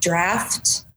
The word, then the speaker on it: draught: It's draft, right? – She's so D-R-A-U-G-H-T is actually pronounced draft, and it's a British spelling.